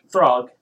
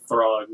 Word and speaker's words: Throg: Throg. Yeah. Small animals. – We should just do an episode where you say Throg and just Throg.